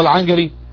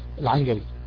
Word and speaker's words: العنقري: العنجري، هي المفروض تكتب العنقري بالقاف، ولكن تنطق هنا يعني شبيهه بالجيم يعني العنجري، وغيرهم من اهل العلم.